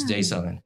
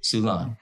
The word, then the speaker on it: Sulan: Nguyen, and with me today, somebody who I've been very much looking forward to speaking with, is Sulan. Sulan. – How are you doing? – Very good. So what is the proper pronunciation? – Sulan. Sulan like Mulan.